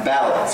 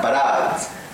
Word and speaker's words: ballads: The ballads, the accent is on ballads, so it's not barads. And I hear so many students say, they're narrative barads. And I go, no, they're not barads, they're ballads.